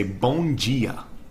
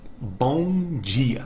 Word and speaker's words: bom dia: In Portuguese it's actually pronounced like a G. So when we say buenos dias in Portuguese, we say bon dia. Bon dia. And that's actually spelled B O M D I A, como B O M D I A.